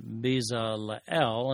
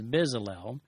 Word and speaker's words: Bezalel: And Hur's grandson, sometimes pronounced Bezalel and sometimes Bezalel,